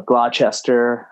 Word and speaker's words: Gloucester: Yeah, well, Gloucester has the same thing that Worcester has, which is like people would want to kind of throw in the ch. So Gloucester, Worcester.